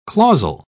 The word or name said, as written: clausal